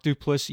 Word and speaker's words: Duplass: And they're played by, it's Patrick Brice, if I'm saying that right, he directed it, and it was written by Brice and, is it Mark Duplass? – Mark Duplass, yep. Duplass.